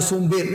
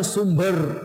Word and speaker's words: sumber: Yang baca sumbir ni, lama saya dah dengar orang ngajar, orang ceramah duk baca sumbir, sumbir. Yang baca sumbir, bacanya sumber. Ber, bukan bir. Sumber artinya, kalau bahasa Arab dia panggil masdar.